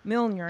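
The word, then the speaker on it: Mjölnir: And basically the story is a woman picks up, I always say it wrong, and Claire's going to cringe when Mjolnir. – She hears it. Mjolnir. – There you go.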